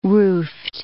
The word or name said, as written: roofed